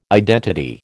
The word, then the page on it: identity: American English